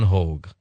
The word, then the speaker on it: Hogue: Hogue. Let me spell it for you, H-O-G-U-E. It sounds like H-O-A-G, but it's H-O-G-U-E, expert on Nostradamus.